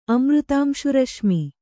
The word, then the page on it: ಅಮೃತಾಂಶುರಶ್ಮಿ: kannada